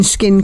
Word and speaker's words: SKCIN: When I was researching skin cancer, I came across a charity I'd never heard of. It's called Skin, but it's spelt S-K-C-I-N because it was founded after the death of Karen Clifford from melanoma in 2005.